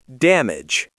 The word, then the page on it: damage: American English